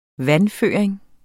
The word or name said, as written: vandføring